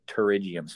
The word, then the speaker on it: pterygium: That's why it's called a pterygium, spelled like a pterodactyl with a P.